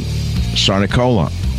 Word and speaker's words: Sarnicola: We have a very special guest today, and that man is Tom Cernicola.